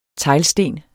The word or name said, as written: teglsten